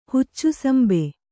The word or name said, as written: ಹುಚ್ಚುಸಂಬೆ